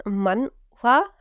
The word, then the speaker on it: Manhwa: Also für all diejenigen, die es nicht wissen, Manhwa, habe ich es jetzt richtig ausgesprochen? – Ja.